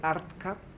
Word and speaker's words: ARTCA: Invitadísimos a el canal de YouTube, es una muy buena entrada, Escuela de Arte, Arca, es Arca como como el ARCA, pero con T en la mitad de las dos sílabas, Escuela de Arte, ARTCA.